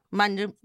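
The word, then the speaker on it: Manjummel: Am I saying it properly? Manjumel? – Manjumel, not bad.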